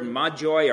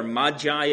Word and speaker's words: magi: Now, that word magoi, or magi as we would pronounce it, is not a word that can be translated, although it has come to mean something associated with magic and magicians.